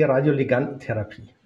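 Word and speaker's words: Radioligandentherapie: Du bist Nuklearmediziner und heute geht es um die Radioligantentherapie.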